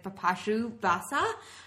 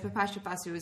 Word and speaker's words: Bipasha Basu: The last time we brought up Bipasha Basu on the podcast, I butchered her name. I think I said Bipasha Basa. I apologize. Bipasha Basu is gorgeous.